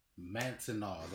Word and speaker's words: Manteno: Mantanaw, that's how you say it.